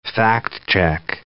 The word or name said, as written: fact-check